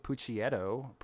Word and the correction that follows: Picciotto: How do you pronounce his last name? – Oh, you very dumb boys. Let Factbot help you. His name is pronounced Guy Picciotto.